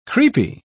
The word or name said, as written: creepy